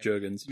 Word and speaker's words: Jurgens: It would be Gazzy Lopez, John Byrne, Matt Wade and probably Dan Yorga. You said I can never say his name. – Jurgens.